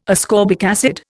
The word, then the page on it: ascorbic acid: Indian